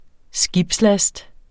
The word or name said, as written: skibslast